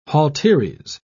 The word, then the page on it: halteres: British English